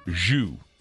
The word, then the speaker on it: Zhou: Here's a guy named Bill, I think it's pronounced Zhou. It's Z-H-O-U.